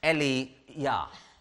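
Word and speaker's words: Eliyahu: Well, the Hebrew word for Elijah is pronounced Eliyah.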